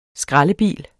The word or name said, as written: skraldebil